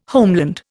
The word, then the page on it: homeland: Indian